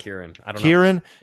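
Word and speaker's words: Ciarán: What's the name? Ciaran? – I think it's pronounced Kieran.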